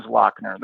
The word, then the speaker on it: Lochner: So then I find out as years go on, I would hear people from Cleveland say Lochner, as in CK, Lochner. And it always kind of, I'm like, is this like some Celtic deal I don't know about, you know? And, the pronunciation or whatever. And then that really is Lochner. That's how it's pronounced. That's how Peter Lochner said his name.